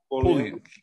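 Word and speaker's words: Pulliam: Am I saying it right? – Pullian.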